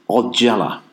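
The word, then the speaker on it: Ojala: That was The Engine by Lost Horizons from their brilliant new album, Odjala. I might not be pronouncing that correctly, so I'm going to spell it for you. It's O-J-A-L-A, and it's on the Bella Union label.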